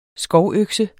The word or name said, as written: skovøkse